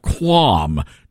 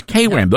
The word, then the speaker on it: KWAM: And then I realized he was phonetically pronouncing KWAM, our flagship radio station, which is KWAM. Those are our call letters.